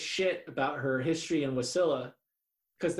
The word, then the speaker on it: Wasilla: I don't know if I've ever heard that word pronounced out loud. Wasilla? Wasilla? – Wasilla. – It's definitely not Wasilla.